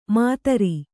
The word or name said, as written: ಮದಿರ